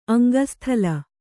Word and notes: ಅಂಗಸ್ಥಲ: aŋgasthala

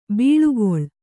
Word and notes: ಬಳಿಗೊಳ್: Kannada

